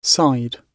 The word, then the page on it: side: British English